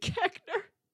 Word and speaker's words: Koechner: The previous year, USA Today had listed Carell, Paul Rudd, David Koechner. Again, is that how you say his name? I don't know. – It is David Koechner.